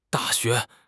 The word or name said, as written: Da4 xue2